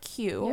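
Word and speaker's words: queue: So the word Q, yeah, forming a line, is just the letter Q followed by 4 silent letters. The word for people trying to spell it out in their heads, it's Q-U-E-U-E. Q.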